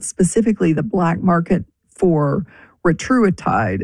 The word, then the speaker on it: retatrutide: So we've heard it pronounced 100 different ways. The one that I like to go with is retatrutide.